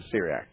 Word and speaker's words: Syriac: am I saying this right? The Syriac. S-Y-R-I-A-C, yeah? – Yeah, oh, yes, Syriac, right. Mm-hmm.